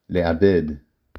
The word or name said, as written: לעבד